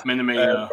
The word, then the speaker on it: Minamino: Somerville being the person that were keeping Minamino, is that the right way to say it? – It's Minamino.